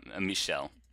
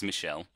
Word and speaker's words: Michel: Because our protagonist, our hero, whose name is, Michel. – Whose name is Michel. – It's Michael without the E, because it's French.